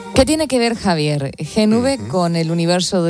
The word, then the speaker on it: Gen V: A ver, ¿es Gen V? ¿Se dice así? – Gen V, exactamente.